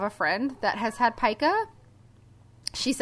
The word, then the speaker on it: pica: So instead, he had read a story about someone with pica, which it is pronounced pica. It's P-I-C-A.